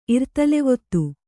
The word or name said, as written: ಇರ್ತಲೆವೊತ್ತು